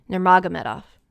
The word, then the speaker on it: Nurmagomedov: How do you say Khabib's last name? – Nurmagomedov. – Close, really close. – Isn't it Nurmagomedov? – Nurmagomedov. You guys are very close.